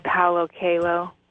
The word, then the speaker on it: Coelho: And where people can also learn about this is if they've read the book called The Alchemist by Paulo Coelho, and I don't know if I'm pronouncing his name correctly. – I think you are, as far as I'm concerned. This, I declare it pronounced correctly.